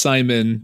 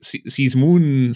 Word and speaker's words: Simon: And Simon, you have, your name is pronounced Simon, just like kind of the, you know, the normal... Yeah. American name. You have a fun spelling. It's like I always hear people call you like Sizmoon, Saziman, it's just Simon.